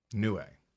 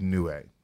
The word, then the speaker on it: Niue: The tiny Pacific Island nation of Niue. Niue. – Okay. – I have a phonetic pronunciation there. I'm not just making this up. It's Niue.